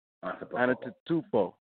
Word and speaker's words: Antetokounmpo: Antetokounmpo. – Antetokounmpo.